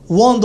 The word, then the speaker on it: Wong: Hoy tengo un invitado muy especial, varios, pero uno que le damos la bienvenida por primera vez en la casa, Ángel Wong. Won dos veces, pero es dos veces de victoria, pero se escribe W-O-N-G, Won.